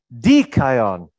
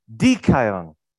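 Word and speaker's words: dikaion (δίκαιον): It says dikaion. See the accent on the beginning? Dikaion.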